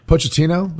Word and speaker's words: Pochettino: By the way, Pochettino, is that how you pronounce the coach's name? – Yeah.